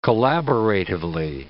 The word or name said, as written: collaboratively